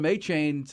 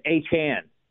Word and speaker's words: Achane: I mean, you were the first one that I heard that from, but we've been calling him Achane since he was in high school, but apparently that's not right. – Yes, Achane.